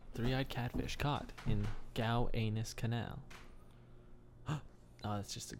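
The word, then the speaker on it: Gowanus: I think it's just pronounced Gow Anus, but Gow Anus is way cooler.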